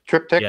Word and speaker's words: triptych: Well, and you get those three portal images in typical architecture of the Masons, the tripictych, I think it's called. Right. Triptych, I think it is more accurately. – Yeah. – Uh-huh. Butchered it the first time, but triptych, as I recall.